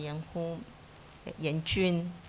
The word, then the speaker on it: Yan Jun: Pero sí lo pudimos hacer, pudimos interpretar la idea que tiene Yan Jun, así pronunciando en chino,